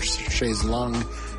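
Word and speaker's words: Chaise Longue: Alrighty, in the last half hour, we got it started with another track from a recent favorite of mine, Wet Leg, and that was Shay's Lounge from, or Shay's Lung, as she pronounces it, from their 2022 self-titled debut release.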